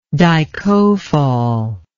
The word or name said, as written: dicofol